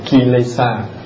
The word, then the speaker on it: kilesa: Tiếng Pali nó đọc là kilesa, còn tiếng Sanskrit là klesha.